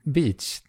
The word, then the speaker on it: beach: Jag tror inte jag sa bitch. Det lät bara som det. – Ja, okej, du sa bitch. – Beach. – Beach. – Beach, absolut.